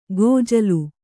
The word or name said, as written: ಗೊಜ್ಜಲು